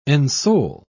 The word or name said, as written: ensoul